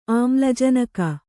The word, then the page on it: ಆಮ್ಲಜನಕ: āmlajanaka